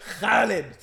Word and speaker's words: Khalid: I mean, and neither here or there, but the brother in the Nation wanted to make sure everyone could pronounce my name in Arabic. It was cute. Khalid. Say Kha. Everyone say Kha.